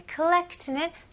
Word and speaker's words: collect: 여기서는 O 발음이지만 호 이런 느낌 아니고 collect 여기는 거의 A 느낌으로 하셔도 돼요. 예를 들어서 누구한테 call 할 때 collect, collect A 하고 U 사이인 것 같아요.